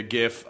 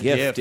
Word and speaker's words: GIF: Well, some people call it a gift, but I call it a gift with a hard G. – You received a gift. – Shit for. – What is it? It's GIF, though. – Well, depending on now the Will Height, the programmer who invented the file format, believes that it's GIF. – Okay. – I happen to like the hard G because we don't say gigabyte, we say gigabyte. – Right. – And I like a hard G before a short vowel.